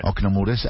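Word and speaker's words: Ocna Mureș: Tu hai iniziato prestissimo, perché nel 2010 per 3 anni eri nella Romania giovanile, nel Ocna Mures. Scusa l'accento che... – No, terrifica. No, è bene, è bene. – Ocna Mures, hai iniziato molto presto.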